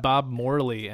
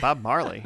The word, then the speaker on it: Bob Morley: There's a guy uh, named Bob Morley, and I said that correctly. yeah, I, I, I saw his name too, and I was like Bob Marley, and then I was like, that's not right.